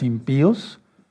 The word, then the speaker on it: impíos: Pero fíjense muy bien, todos, no lo digo yo, lo dice la Biblia, los impíos, impíos, impíos, es acento de ruptura para aquellos, eso es gramática, pero para que no se vayan a espantar, porque está poniendo el acento en la I. Ese es un acento de ruptura, así para que se rompa el diptongo.